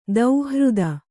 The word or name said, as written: ದೌಹೃದ